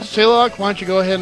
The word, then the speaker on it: Selok: How do you say her name? Or his name? S-E-L-O-K? – Selok, I think. – Selok. Will you please get your information to Jethel?